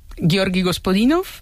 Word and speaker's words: Georgi Gospodinov: A ver, Georgi Gospodinov, sí.